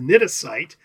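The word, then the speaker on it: cnidocyte: Tentafor is spelled C-T-E-N-T-O-P-H-O-R-E. – Uh-huh. – So now you have a stinging cell of a jellyfish, and it's pronounced a nidocyte. And can you guess the pronunciation, the spelling? – Sure, it starts with a C.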